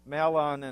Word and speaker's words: Mahlon: Famine in the land, journeying to Moab, marriage, the death of Elimelech and Mahlon and Chilion. And Bonnie, you got those dead on right, the pronunciation.